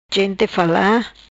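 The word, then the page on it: Gente Fala: patua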